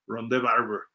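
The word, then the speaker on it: Rondé Barber: Ahí está Ronde Barber, tiene acento en la E, ahí no lo pusimos, pero Ronde así se pronuncia Ronde Barber.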